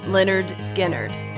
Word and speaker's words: Lynyrd Skynyrd: Simple Man was released on the band's 1973 debut album, pronounced Lynyrd Skynyrd.